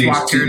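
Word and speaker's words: Wachter: W-A-C-H is actually pronounced Watcher. Don't correct me. T-E-R-H-A-U-S.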